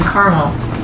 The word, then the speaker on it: Carmel: Neither miss they anything all the days they were in Carmel. Is that how you say it? – Yeah. Mm-hmm. – Okay.